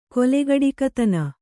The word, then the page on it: ಕೊಲೆಗಡುಕತನ: kolegaḍukatana